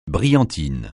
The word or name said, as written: brillantine